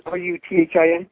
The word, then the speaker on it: Ruthin: I think the most famous one that I know of is it, you pronounce it Ruthen or Ruthen? Castle. – Don't know that one personally, but Ruthin.